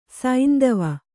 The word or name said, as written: ಸೈಂದವ